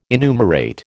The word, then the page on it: enumerate: American English